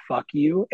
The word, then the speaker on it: Phuq: But there's, when you get to their second record, it's called Fuck You, and it's spelled P-H-U-Q.